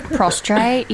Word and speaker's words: prostrate: Oh, okay, because it sounds a lot like prostate, which is obviously... – Prostrate? – Different. – Am I saying that right? It's like, I picked it up, like, mid-century, I think. – Prostrate? Yeah.